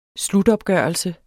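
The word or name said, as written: SLUTOPGØRELSEN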